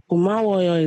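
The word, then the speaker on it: Komawoyo (고마워요): And it's spelled E-O-N-N-I, Onni. That's the romanization. Or you can do U-N-N-I. And then Komawoyo is G-O-M-A-W-O-Y-O, Komawoyo.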